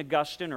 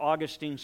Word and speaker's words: Augustine: And there was a man by the name of St. Augustine, or Augustine, some people pronounce his name,